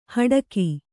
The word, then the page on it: ಹಡಕಿ: kannada; haḍaki